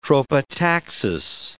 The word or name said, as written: tropotaxis